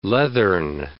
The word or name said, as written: leathern